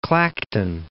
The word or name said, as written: Clacton